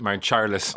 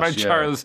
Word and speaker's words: Mount Charles: In Donegal, we pronounce it Mount Charles, you know, as opposed to Mount Charles, which is an unusual Donegalism, but that's what it is.